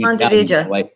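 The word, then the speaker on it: Ponte Vedra: I go with the Latin pronunciation being Pontevedra. Pontevedra Beach.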